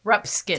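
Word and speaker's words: Ruxpin: Am I saying that right? – Oh, Rupskin.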